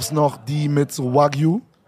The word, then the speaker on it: Wagyu: Und dann hatten wir Wagyu, haben wir uns bestellt. Hab ich es richtig ausgesprochen? – Ja, sehr gut.